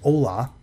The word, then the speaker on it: olah: I believe it's pronounced olah, though I have not taken Hebrew yet, and I know that does sound Spanish, but O-L-A-H.